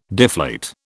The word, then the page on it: deflate: British English